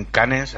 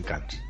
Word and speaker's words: Cannes: Perdona, yo prefiero que se estrene en Cannes antes que en Cannes. Cannes, pero es que tenemos que decirlo de, los dos idiomas, ya sabes.